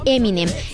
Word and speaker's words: Eminem: Entonces adoptó la pronunciación y la fonética a Eminem.